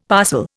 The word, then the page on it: parcel: Indian